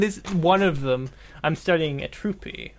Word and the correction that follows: Euterpe: Yeah, Euterpe.